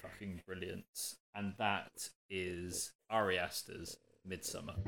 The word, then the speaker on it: Ari: Yeah, it's Ari.